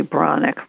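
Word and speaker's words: Breathnach: Yeah, I love a quote that Sarah Ben, am I saying her last name, Ben Brusnak? Yes. – It's actually Bronick, which makes no sense, but that is how you pronounce it.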